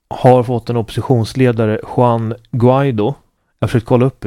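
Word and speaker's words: Guaidó: Det ska uttalas med ett hårt G. Som är Guaido. – Ja. – Och ungefär, diftongen låter ungefär så.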